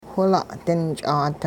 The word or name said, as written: whila'-dinch'aat